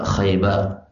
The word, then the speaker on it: Khaybar: Khaybar cara bacanya seperti Ja'far. Ja'far Khaybar.